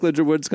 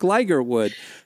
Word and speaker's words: Ligertwood: okay we I, just uh, listeners you know I said Brooke Ligertwood's coming up you're probably like who's Brooke Ligertwood because for ten years you have been telling us about Brooke Ligerwood we found out I, have been saying her name wrong.